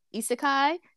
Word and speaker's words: isekai: So now we're entering isekai.